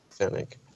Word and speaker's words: Fenech: Because I was saying Fennec. – Yes, I know. – Yeah, it's Fennec. – Oh, it's Fennec.